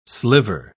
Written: sliver

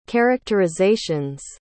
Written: characterization's

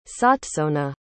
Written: sotsona

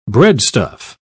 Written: breadstuff

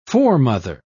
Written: foremother

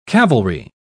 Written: cavalry

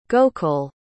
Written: gokul

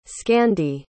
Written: scandy

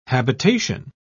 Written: habitation

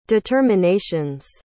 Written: determinations

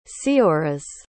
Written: Seoras

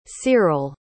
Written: cyrill

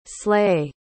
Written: slae

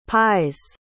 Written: pies

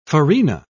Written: farina

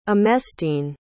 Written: emestine

